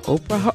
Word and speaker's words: Oprah: O-P-R-A-H. Oprah? Oprah? No, the H is silent.